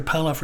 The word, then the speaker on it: Pallof: Then pull-off rotations, it's spelled P-A-L-L-O-F if you want to look those up on YouTube.